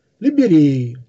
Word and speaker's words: Либерея: Именно поэтому ученые назвали библиотеку царя «Либереей» с ударением на предпоследний слог.